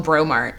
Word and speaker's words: Breaux: I don't know why it's called Bro Mart. But there's a shop called Bro Mart New Orleans that's spelled, like, B-R-E-A-U-X. The way we spell, like, everything French here.